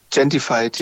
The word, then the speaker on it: Gentrified: Und zwar ist es hier Gentrified, wenn ich es richtig ausspreche und richtig sehen kann. – Gentrified, ja.